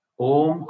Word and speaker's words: Om: Om comprises of three sounds, A-U-M. Yeah, you see it written as A-U-M. So when Om is chanted, it comes out as A-U-M, A-U-M.